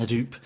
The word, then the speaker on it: Hadoop: Hadoop data lakes. Am I pronouncing that correctly? Yeah. Uh, Hadoop data lakes to Amazon S3 um, and expanding its uh, global platform to help uh, the bank better serve its European customers.